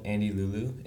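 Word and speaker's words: Andeliuliu: My channel is called Andy Lulu. It's A-N-D-E-L-I-U-L-I-U, and many people spell it like Andy as A-N-D-Y, which is confusing when I tell you, but yeah, that's my channel name.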